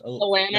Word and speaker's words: Alanis: Alicit. I always pronounce that the lady's name. – Alanis Morissette. – Yes, Alanis.